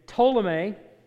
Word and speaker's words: Ptolemy: And Ptolemy, you don't pronounce the P as far as I know. Maybe he did, I don't know. But it's been lost since then, and everybody just calls him Ptolemy now.